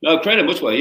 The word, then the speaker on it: Credo Mutwa: You got to meet and did you study with, I'm going to butcher his name right now, Credo Mutwa? – Credo Mutwa, yeah, that's right.